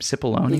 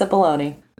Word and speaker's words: Cipollone: Cipollone? – You got it. – That's it? – Yes, Cipollone.